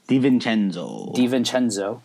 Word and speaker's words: DiVincenzo: And based on, again, Gambo's report, and he had a tough evening, but on his report, had they taken DiVincenzo, did I say it right? – DiVincenzo.